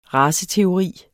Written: raceteori

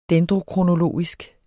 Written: dendrokronologisk

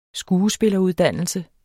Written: skuespilleruddannelse

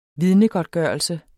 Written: vidnegodtgørelse

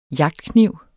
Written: JAGTKNIV

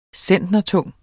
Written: centnertung

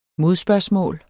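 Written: MODSPØRGSMÅL

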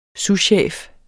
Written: souschef

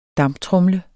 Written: damptromle

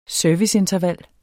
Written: serviceinterval

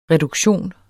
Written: reduktion

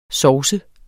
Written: sovset

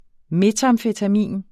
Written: metamfetamin

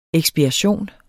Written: eksspiration

